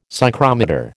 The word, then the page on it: psychrometer: American English